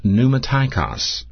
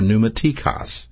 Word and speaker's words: pneumatikos: The Greek word is pronounced pneumatikos, or pneumatikos. And it's spelled P-N-E-U-M-A-T-I-K-O-S, pneumatikos.